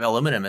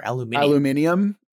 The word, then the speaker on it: aluminium: Although their pronunciation of aluminum is so much cooler. Aluminium.